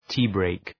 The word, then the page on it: tea break: ‘ti:,breık